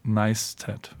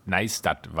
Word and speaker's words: Neistat: wie wird er richtig ausgesprochen? – Neistat, würde ich sagen. – Neistat war da.